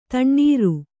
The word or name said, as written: ದಿನಾರು